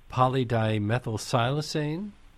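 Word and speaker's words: polydimethylsiloxane: In some cases, like wines, the, we don't have ingredients listed, but uh, some wines have polydimethylsilicene. – Did uh, I pronounce that right? Yes.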